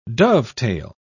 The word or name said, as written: dovetail